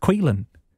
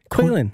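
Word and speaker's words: Caoilfhionn: This is how it's spelled. C-A-O-I-L-F-H-I-O-N-N. C-A-O-I-L-F-H-I-O-N-N. And that name is pronounced Quelan. – Quelan.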